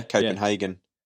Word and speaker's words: Copenhagen: How do you say Copenhagen? I've always said Copenhagen. – I say it that. Yeah, Copenhagen.